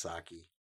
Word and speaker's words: Psaki: I guess it's Psaki. The P is silent.